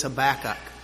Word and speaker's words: Habakkuk: We turn in our Bibles to the book of Habakkuk. It's found in page 1307. Having questions about the pronunciation, I did look it up, and the official pronunciation supposedly is Habakkuk.